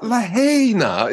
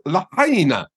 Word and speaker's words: Lahaina: And they even put up, you know, a transliteration on the screen, L-A-H-H-A-Y. It's not Lahaina, it's Lahaina. So it's just laziness.